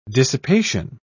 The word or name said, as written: dissipation